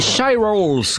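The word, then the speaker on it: Shirelles: Les Shy Rolls, comme ça se prononce, Mama Said, un titre de 1961.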